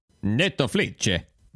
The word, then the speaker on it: Netflix: Speaking of Italians, do you know how they pronounce Netflix in Italy? – No. – Netoflice.